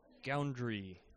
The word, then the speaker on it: Goundry: So, Ruby on Rails podcast, with Andrew Goundry.